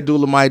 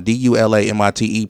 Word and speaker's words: Dulamite: Please say my name right. – It's not Dola. – It's not Dolomite. It's Abdullah and Dulamite together. – Nice. – Dulamite. – I feel it. – Yeah. – So that's why it's spelled the way that it is.